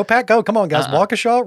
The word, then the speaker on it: Waukesha: That's just Waukesha.